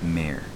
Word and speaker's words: Mare: You keep pronouncing it Mar, but it's Mare.